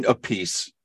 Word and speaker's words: Appice: It's Carmine Appice and Vinny Appice.